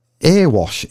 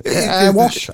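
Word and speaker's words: Erewash: This is Earwash. Earewash. Earwash Council. Earwash Council. – Could you just spell it again? – ERE. – Yeah. – WASH. – Earwash.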